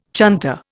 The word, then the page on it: chunter: Indian